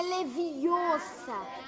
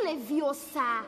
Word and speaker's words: Leviosa: E você está falando errado. É Leviosa. E não Leviosa.